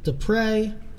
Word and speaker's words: Deprez: All right, so his name is spelled D-E-P-R-E-Z, Dupre. His name is Louis Dupre.